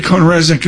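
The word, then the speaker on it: CohnReznick: On Jobs Day, we spell it Cone Resnick, CONREZNICK, Cone Resnick, and we thank them for true foundational support of the conversations we have.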